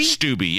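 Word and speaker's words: Steube: How do you say his name? Stubbe? – Stubbe, yes, Stubbe. That's correct.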